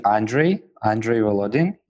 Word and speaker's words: Andrey Volodin: I guess in English, it should be Andrey Volodin.